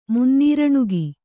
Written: ಮುನ್ನೀರಣುಗ

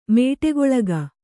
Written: ಮೇಟಿಗೊಳಗ

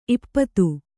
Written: ಈಪ್ಪತ್ತು

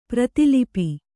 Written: ಪ್ರತಿಲಿಪಿ

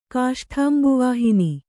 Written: ಕಾಷ್ಠಾಂಬುವಾಹಿನಿ

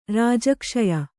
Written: ರಾಜಕ್ಷಯ

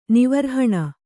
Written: ನಿವರ್ಹಣ

